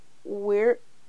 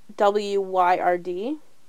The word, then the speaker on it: Wyrd: Several songs have also been written about the event, event such as This Memory by the Weird Wired Sisters. I want to say weird. It's spelled W Y R D. – I think it is Wyrd Sisters.